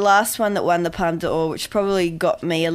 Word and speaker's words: Palme d'Or: I think things that go for the Palme d'Or as well. Did I say that right? – Yes, you did.